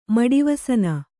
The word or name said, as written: ಮಡಿವಸನ